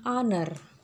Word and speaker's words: honor: Misalkan an honor. Honor itu tulisannya pakai H ya. Honor. Tapi dia bacanya an honor. Jadi kita pakai an karena ketemunya H itu dibacanya vokal ya.